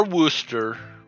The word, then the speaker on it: Wooster: Our Wooster is actually spelled how you would think Wooster would be spelled, unlike the Worcester in frickin' Right? – Massachusetts, which is spelled like Worcestershire shosh.